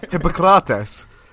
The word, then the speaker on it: Hippocrates: Hippocrates.